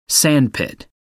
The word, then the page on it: sandpit: American English